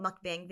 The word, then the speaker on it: mukbang: Do you know what mukbang videos are? – Mukbang.